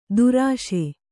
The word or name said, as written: ತರ್ಷ